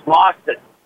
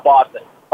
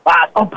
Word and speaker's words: Boston: I said you need to pronounce it right. It's Boston. Not Boston. Boston. That's how we say it here.